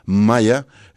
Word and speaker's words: MIA: es MIA, no, aquí no es como ella que es, se pronuncia Maya, es la rola, es de hecho parte de, te digo, del álbum Hypnos, aunque también salió como sencillo.